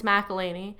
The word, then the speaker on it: McElhaney: Like, McElhaney is one of those names where I'm always like, McElhaney? And I'm like, no, it's McElhaney.